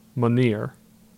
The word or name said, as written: Minier